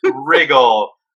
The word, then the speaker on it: Riggle: Riggle. But I answer to anything with an R. Riggle, Riggle, Riggle. I've heard it all. – It's pronounced Riggle. Okay. It's Riggle. – It's French. It's French. Riggle.